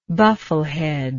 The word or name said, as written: buffleheaded